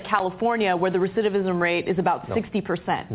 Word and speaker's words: recidivism: I can't believe the recidivism rate is. – We both said it right.